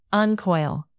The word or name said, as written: uncoil